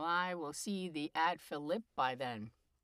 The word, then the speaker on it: Philippi: And actually, I'm pronouncing it because that's the way the Romans pronounced it. But in Shakespeare's scansion, if you do it, it's Philippi.